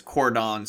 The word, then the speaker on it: Cordon: You know, you're pronouncing that incorrectly. It's Cordon Sullivan. – Cordon? – Yeah, like Cordon Bleu.